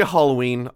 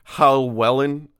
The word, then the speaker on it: Hallwellen: One story goes that Johann and Thomas Tinner were in an area the locals knew as, I'm going to say Halloween. I know that's not how you say it. It's Hallwellen.